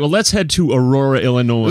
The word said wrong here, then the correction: Illinois: Illinois. Oof. I just pronounced the S at the end of Illinois.